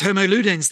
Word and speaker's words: Homo ludens: You have also pointed out that some have argued our species should be called not Homo sapiens, but Homo ludens. Am I saying that correctly? – Yes, Homo ludens, the playing species.